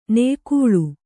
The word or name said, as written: ನಾಯಿಗಳ್ಳಿ